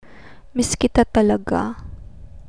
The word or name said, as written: miss kita talaga